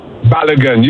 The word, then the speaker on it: Balogun: Balogun.